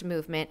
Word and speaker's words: MVMT: And that's why I love Movement watches, and that's MVMT, but pronounced Movement,